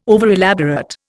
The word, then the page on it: overelaborate: Indian